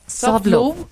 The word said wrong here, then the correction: soft lube: Con soft love de Zico. – Soft love. – Ajá, soft love. – Okay. Soft lube. – Lube.